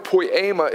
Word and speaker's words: poiema: The way you spell that word is P-O-I-E-M-A, and it is pronounced poiema, poiema.